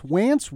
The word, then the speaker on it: Wance: Wance rhymes with pants.